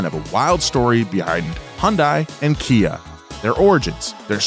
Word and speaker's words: Hyundai: It's Hyundai. – Hyundai. – Rhymes with Sunday.